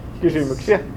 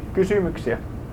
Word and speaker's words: Kysymyksiä: Kysymyksiä. Kysymyksiä.